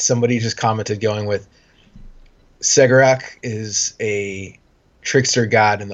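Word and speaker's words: Cegorach: So we're going to, for our intents and purposes, call him Segarak. It's the way it's pronounced, the way it's spelt is open to multiple different pronunciations, but this is going to be the Foxtrot battle line one.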